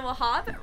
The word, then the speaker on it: Wahab: And my name is Mina Wahab, rhymes with kebab.